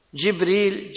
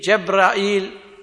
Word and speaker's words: جبريل: وجبرائيل فيه عشر لغات، ها كيف ما أنطقته يصح، جبريل، جبرائيل، ها بكسر الجيم جبريل، بفتح الجيم جبريل، جبرائيل، جبرائيل، جبرائيل، جبرائيل، فيه قراءات كثيرة عند القراء مشهور، وهو اسم أعجمي قيل إن معناه عبد الله، والله أعلم.